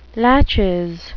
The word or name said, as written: laches